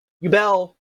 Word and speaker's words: Yubel: Yubel, apparently it's the correct way of pronouncing it,